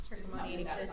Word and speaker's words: trichomoniasis: Trichomoniasis.